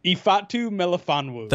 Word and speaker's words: Ifeatu Melifonwu: So Ifeatu, even though there's an E in it.